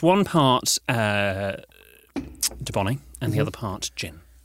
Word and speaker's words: Dubonnet: Dubonnet. – Dubonnet. – It's spelled French. D-U-B-O... – Double N. – Double N-E-T.